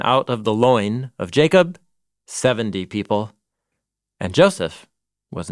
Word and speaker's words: Yaakov: And these are the names of the sons of Israel, the ones who went to Egypt with Yaakov. – Jacob. – Jacob, yeah. Sorry, I'm trying to use Hebrew pronunciation in my translation.